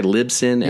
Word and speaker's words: Libsyn: Am I pronouncing that right? Libsyn? – Yeah, Liberated Syndicate.